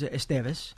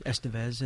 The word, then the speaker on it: Estevez: Well, the Spanish way is Estevez, mm-hmm. but the gringo pronunciation is Estevez, and I'm fine with either, frankly.